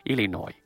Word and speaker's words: Illinois: 然后提到这几个人和他的同事，他们的同事，and their colleagues，在西北大学，伊利诺伊州的西北大学这个地方，它拼写是 Illinois，但是这个它是不发音的 Illinois。